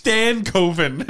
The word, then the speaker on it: Stankoven: It's Stankoven. That's Stankoven. – It's spelled Stankoven.